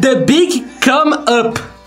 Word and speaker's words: The Big Come Up: Repete a pronúncia? – Não. Como é que é? – Não, eu não, lê aí. Lê você, pô, tá mostrando por quê. Pode ir. The Big Come Up.